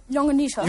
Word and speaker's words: llonganissa: A ver cómo pronuncias la L. – Llonganissa. – Llonganissa, eh? Llonganissa.